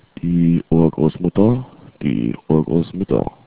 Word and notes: urgrossmutter: german

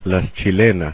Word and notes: chilenas: Spanish

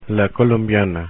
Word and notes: colombiana: Spanish

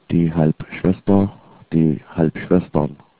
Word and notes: halbschwester: german

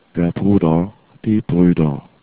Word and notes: bruder: german

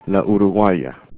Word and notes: uruguaya: spanish

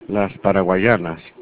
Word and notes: paraguayanas: Spanish

